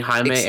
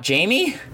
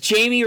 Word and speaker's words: Jaime: Is that how his name is pronounced? – Jaime, yes. – It's not Jamie? – No. – It says Jaime Reyes to me there. – No, it's Jaime.